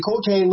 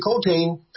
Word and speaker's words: Coltrane: But the way that I remember that it was him is because I kept getting his name wrong. Mr. Coltrane, Mr. Coltrane. And I forget if it was my dad or my older sister who said, no, it's like the rail yard, like a coal train.